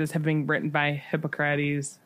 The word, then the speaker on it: Hippocrates: Hippocrates, right? – Hippocrates, thank you.